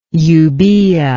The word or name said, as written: euboic